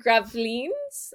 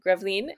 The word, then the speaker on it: Gravelines: So he sails to Gravelines. I probably said that wrong. Gravelines. And he met the bastard there.